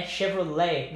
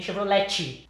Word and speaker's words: Chevrolet: And I think the long phrase for it is Chevrolet. – Chevrolet, yeah. Nice. Okay, cool. Então, olha aí, ó. A segunda é Chevrolet. Não é Chevrolet, nem Chevrolet. Chevrolet. Ou Chevy, né? – Right?